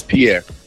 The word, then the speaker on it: Pierre: Yes, Pierre.